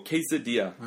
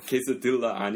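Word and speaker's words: quesadilla: 그래서 우리 진짜 타코랑 브리토랑 이런 거 케이사디아 네. – 그 L 두 개 나타날 때 네. Y 발음을 해요. 케이사딜라 아닙니다. 그러니까 네 케이사디아 이런 거 많이 먹는데 한국에 처음 왔을 때 멕시코 음식 찾기 너무 힘들었어요.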